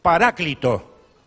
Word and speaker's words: Paráclito: La fonética o pronunciación es Paráclito, así se pronuncia, pero se escribe Paracleto.